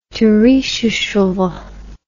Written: Turishchevo

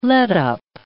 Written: letup

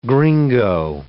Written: gringo

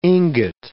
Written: ingot

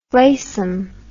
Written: fleysome

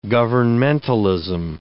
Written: governmentalism

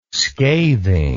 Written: scathing

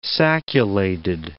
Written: sacculated

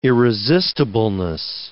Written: irresistibleness

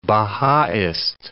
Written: Bahaist

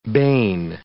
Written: bane

